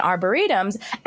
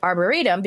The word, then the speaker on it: arboretum: Then he started talking about the botanical gardens and arboretums. And then he argued with me about the way I say the word arboretum because I put emphasis on the O instead of the E.